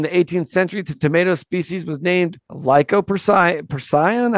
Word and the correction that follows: Lycopersicon: How do you say that? Lycoper- Lycopersicon. Persicon. Okay.